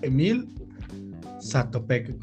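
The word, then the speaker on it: Emil Zátopek: Se llama Emil Satopec, como dices tú.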